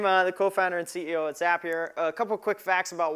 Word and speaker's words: Zapier: We have about 2.5 million users, and yes, Zapier rhymes with happier, in case you were curious.